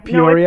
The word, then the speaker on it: Peoria: Peoria. – No, it's Peoria, but the conference is actually across the river in East Peoria, but it's kind of like the whole Peoria area.